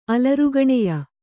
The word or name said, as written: ಅಲರ್ಗಣೆಯ